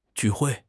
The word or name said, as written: ju4 hui4